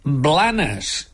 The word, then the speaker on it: Blanes: En català central pronunciem Blanes, igual com fem amb el plural de la plana. Una plana, dues planes. Només hi ha dues situacions concretes en les quals té una certa lògica lingüística pronunciar Blanes. En primer lloc, si hables en castellano, pues Blanes, como Barcelona, Blanes.